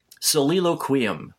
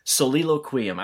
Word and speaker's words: Soliloquium: And he's looking at it like, so between the two of us, we figured it out. It's, okay. Soliloquium. – Okay. – Soliloquium.